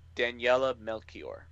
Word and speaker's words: Daniela Melchior: As Polka Dot Man, Danila Melchior as Ratcatcher 2. – If I may, Daniela Melchior.